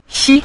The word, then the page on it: xi: xi5